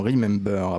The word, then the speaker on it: Remembeur: Ali Gessoum, fondateur de l'association Remember, a prononcé BEUR, est auteur du livre Attention, euh, travail euh, d'arabe.